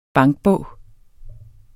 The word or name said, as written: bankbog